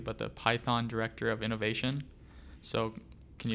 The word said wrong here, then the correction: Pithan: Um, as director of the Student Innovation Center, so it's pronounced Pithan. It's a Pithan.